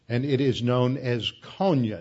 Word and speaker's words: Konya: Now, Iconian, if you pronounce the final consonant with an N instead of an M, which is typical as words go from one language to another, that M to N is a typical change. Iconium and Konya. You can hear the modern Turkish name Konya in the middle syllable of Iconium.